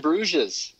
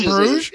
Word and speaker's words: Bruges: You mean In Bruges? – Is an amazing movie. – I don't think they pronounce it In Bruges? – Yeah, it's not that. They don't pronounce the ES.